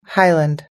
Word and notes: highland: American English

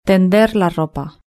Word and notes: tender la ropa: European Spanish

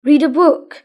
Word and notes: read a book: British English

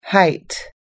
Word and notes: height: American English

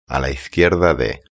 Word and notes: a la izquierda de: European Spanish

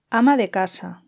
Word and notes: ama de casa: European Spanish

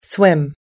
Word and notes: swim: American English

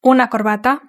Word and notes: una corbata: European Spanish

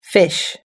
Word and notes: fish: American English

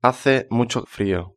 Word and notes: hace mucho frio: European Spanish

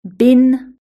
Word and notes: bin: british-english

